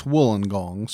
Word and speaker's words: Wollongong: You would think. – But I've been told that it's Wollongong, so I'm going with that.